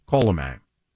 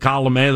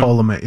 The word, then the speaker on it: Colomé: Proper way to say it. Alexander Colomé. – Yes. – There are those who had said Colomé. There's a little terrorism. – Colomé, yeah, Colomé.